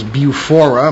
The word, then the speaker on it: BUFORA: Tony Eccles has been a member of the British UFO Research Association, otherwise known as BUFORA. Am I pronouncing that correctly? – Yeah, that's right.